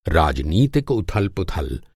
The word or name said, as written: उथल-पुथल